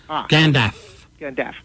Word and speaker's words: Gandalf: Rhymes with half. Gandalf.